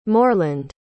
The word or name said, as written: moreland